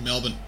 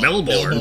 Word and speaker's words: Melbourne: And they actually pronounce Melbourne correctly. It's Melbourne, not Melbourne.